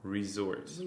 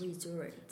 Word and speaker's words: resort: 그래서 중요한 것은 R 발음을 이렇게 살려서 resort. – resort. – 그리고 그 SZ처럼 되는 거잖아요. So resort.